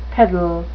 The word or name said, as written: piddle